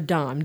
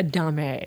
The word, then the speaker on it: domme: You're a dom. D O M M E. The second M and the E is silent. It's a made up word, anyhow, but you are not a dame.